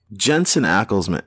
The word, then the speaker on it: Jensen Ackles: and Jensen Ackles, that's how you pronounce his name, right? – Yeah.